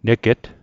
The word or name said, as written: neget